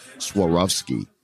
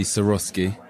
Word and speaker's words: Swarovski: I always thought it was Swarovski art. But welcome to the show, and maybe you can help me pronounce this correctly. – Hi, yes, It's actually Swarovski.